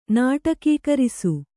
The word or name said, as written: ನಾಟಕೀಕರಿಸು